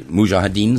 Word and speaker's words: Mujahideen: I mean, there you were with the Mujahideen, is that the pronunciation? – Yeah.